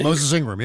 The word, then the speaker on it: Moses Ingram: Was it Moses Ingram? I think Moses Ingram.